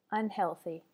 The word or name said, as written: unhealthy